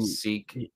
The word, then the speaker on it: Seq: Only this time, you're introduced to new characters, Bugs, played by Jessica Henwick, and a character named, I think his name is Seek. It's spelled S-E-Q, and I'm not sure who... – Yeah, Seek.